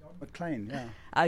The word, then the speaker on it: McLean: McLean, yeah.